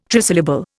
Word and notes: trisyllable: Indian

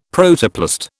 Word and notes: Protoplast: British English

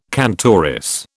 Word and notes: cantoris: British English

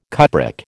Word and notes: cupric: American English

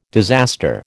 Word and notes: disaster: American English

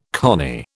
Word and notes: cony: British English

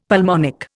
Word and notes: pulmonic: Indian

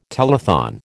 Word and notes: telethon: American English

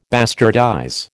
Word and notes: bastardize: American English

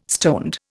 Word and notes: stoned: Indian

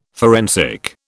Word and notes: forensic: British English

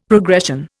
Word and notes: progression: Indian

